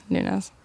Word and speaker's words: Nunes: Yeah, Nunes. – Oh, sweet. – See, I didn't know if it was Nunes or Nunez, and I didn't want to be that dick that said it wrong.